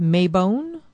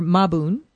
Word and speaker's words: Mabon: So this week, actually, Wednesday, the 22nd, is the autumnal equinox, or as it's known in witchcraft, Mabon or Mabun, two different ways to pronounce it.